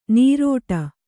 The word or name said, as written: ನೀರೋಟ